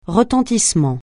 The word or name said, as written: retentissement